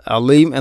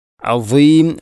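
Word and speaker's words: അസീം (عظيم): അസീം പലപ്പോഴും നമ്മൾ പറയുന്നത് പലപ്പോഴും എന്നല്ല അധികവും നമ്മൾ അലീം എന്നാണ്, അധികം ആളുകളും അലീം എന്നാണ് പറയുന്നത്. അഥവാ സ്വാദിന് പുള്ളിട്ട ദ്വാദും താന് പുള്ളിയിട്ടാൽ ഉള്ള ദ്വാദും അതുപോലെ അള്ളാഹു എന്നതിലെയും ഉച്ചാരണമൊക്കെ നമ്മൾ ഒരുപോലെയാണ് പറയാറുള്ളത്. അത് ശ്രദ്ധിക്കണം. അസീം അസീം എന്ന് തന്നെ പറയണം.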